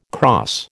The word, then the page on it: crosse: American English